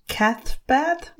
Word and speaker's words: Cathbad: So there's the druid Cathbad. – Yeah, I think that's it. – Not sure, but... – I listened to an audiobook with that name in it, and that's how they pronounce it.